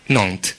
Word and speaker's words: Nantes: Perdona, come si pronuncia, come si pronuncia in francese la città di Nantes? – Nantes.